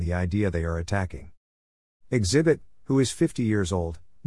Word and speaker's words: Xzibit: But with Xzibit, phonetically it's pronounced Xzibit, there was always a bravado that had the ability to create gangster songs while being able to entertain the masses.